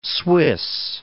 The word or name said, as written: Swiss